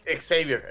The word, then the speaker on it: Xavier: Y entonces el tema es que Gabriel, ni el mismísimo profesor Javier X pudo haber enfrentado una. – Se pronuncia Javier o Xavier. – Xavier, el profesor Xavier.